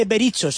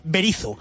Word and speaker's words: Berizzo: El equipo del Toto Berizzo, Eduardo, que por fin me dijeron Mauri, no se dice Bericho, se dice Berizzo.